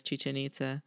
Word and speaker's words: Chichen Itza: And so one of these was like, this famous ancient city rhymes with chicken pizza. – Jeez. – And the answer was Chichen Itza.